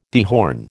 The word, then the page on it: dehorn: American English